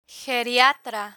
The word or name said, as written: geriatra